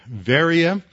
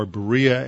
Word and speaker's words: Berea: And so he, uh, from there, he had to leave because of persecution, opposition, and he headed to Veria, or Berea as it's pronounced in English, but at Veria, again, he meets in a, a, synagogue with the Jews.